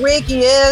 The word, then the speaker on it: Reiki: I've seen on Instagram that you do all kinds of different therapy, namely, please, if I mispronounce it, help me right, Reiki healing. – Yes, you did pronounce it right. It's Reiki, thank goodness.